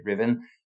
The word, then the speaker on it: Ruthven: She spelt Ruthven, R-U-T-H-V-E-N, but you say Riven.